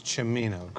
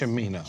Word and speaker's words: Cimino: If I say his name, it is Chimino, correct? Yes. Chimino. Chimino. Okay.